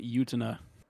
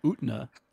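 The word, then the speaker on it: Utena: That's a minus for pronouncing it Yutena. – Well, that's how you've been saying it, so I just copied it. No, I say Utna. – No, you don't.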